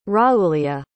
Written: raoulia

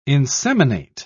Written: inseminate

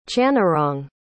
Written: channarong

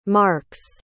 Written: marks

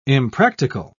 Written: impractical

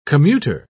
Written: commuter

